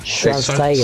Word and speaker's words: Schwarzenegger: Sí, Schwarzenegger.